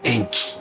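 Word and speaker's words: 塩基: 在这个地方其实有一个巧合啊，就是 DNA 结构的单位啊叫碱基，碱基啊日语里边叫盐基，盐基读作 Enki 啊。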